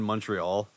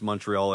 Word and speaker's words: Montreal: When he pronounced Montreal as Montreal, he's like, "Why don't they pronounce the T? It's clearly there."